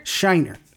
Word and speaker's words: Scheinert: Scheinert.